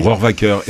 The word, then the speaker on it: Rohrwacher: Comment vous prononcez ? – Rohrwacher. Et en principe, il faudrait même dire Alice.